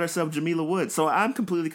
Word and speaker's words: Jamila: she says that her name has a long I. So I was like, oh, I've been saying it wrong. It's Jamila Woods.